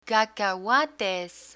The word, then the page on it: cacahuates: spanish